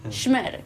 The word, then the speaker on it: SHMER: SHMER.